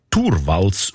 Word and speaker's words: Torvalds: Der Linus Torvalds, eigentlich Torvalds ausgesprochen, damals Informatikstudent Helsinki, er hat die wertvollste Software der Welt namens Linux einfach verschenkt.